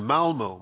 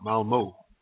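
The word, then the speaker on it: Malmö: I'm in Malmo, Sweden. It's south. – Spell that. – It's M-A-L, and then you have an It's M-A-L-M, and then you have an O with two dots on it on the top, Malmo.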